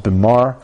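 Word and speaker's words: bimah: The platform and the desk for the Torah readings are called the Bima, pronounced Bimar.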